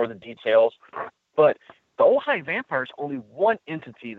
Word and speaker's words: Ojai: The town is Ojai, but it's spelled O-J-A-I, California. And you can kind of put in Ojai, that name, O-J-A-I, and it's vampire.